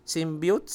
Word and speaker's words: symbiote: Oh, yung yung ah, how do you pronounce? Symbiote, symbiote.